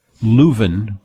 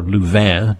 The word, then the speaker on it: Leuven: It's Leuven or Louvain, depending on whether you're pronouncing it the Flemish way or the Dutch way or the French way.